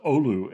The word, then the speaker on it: Oulu: So the small town in northern Wisconsin, Oulu, most people say now, was traditionally, it's a Finnish town named after a town in Finland, city in Finland, and it was traditionally pronounced Oulu. And there's still people in town who say it that way, but now it's mostly Oulu.